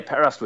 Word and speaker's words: Perast: Perast.